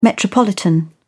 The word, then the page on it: metropolitan: British English